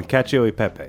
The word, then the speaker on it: Cacio e Pepe: This, how do you pronounce that? Cacao Pepe? – Oh, keep going with that one. Cacio e Pepe.